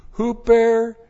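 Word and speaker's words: Huper (ὑπέρ): Huper periselwo. That's how it looks in the Greek. H-U-P-E-R. Huper. P-E-R-I-S-S-E-U long O.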